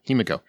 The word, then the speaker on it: Himiko: Himiko.